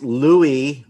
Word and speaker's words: Louis: It's Louis, like the French way.